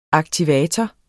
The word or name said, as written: aktivator